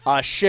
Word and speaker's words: AXE: The deliberate lie was that I never went to a restaurant called AXE, pronounced Ashe.